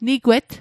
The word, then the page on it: nigwet: Mi'gmaq